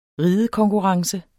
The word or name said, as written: ridekonkurrence